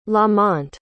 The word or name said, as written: lamonte